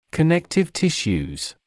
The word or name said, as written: connective tissues